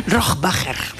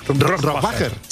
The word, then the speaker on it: Rohrbacher: Rohrbacher. – Rohrbacher.